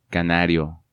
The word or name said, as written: Canario